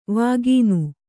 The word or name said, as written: ವಿಗಾನ